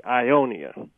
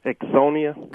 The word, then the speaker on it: Ixonia: It's spelled I-X-O-N-I-A. – Uh-huh. – I was taught to say Ionia. But I'm not even sure if that's the correct pronunciation. – Ixonia. – Ixonia? – Correct, yes. – Yep, it's Ixonia.